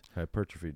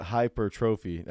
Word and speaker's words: hypertrophy: Hypertrophy day. – How do you say that word again? – I actually was struggling there.